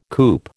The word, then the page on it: coop: American English